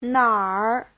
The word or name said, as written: nar3